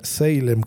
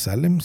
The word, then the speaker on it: Salem: Pues ahí está su señor hijo comprándole los vuelos para que pueda irse a Salem, que es en donde está en este momento. Algunos lo pronuncian Salem, no es Salem, Salem, en Oregon, muy cerca de Portland.